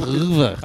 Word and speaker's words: oeuvre: I thought ouvre. – It's ouvre.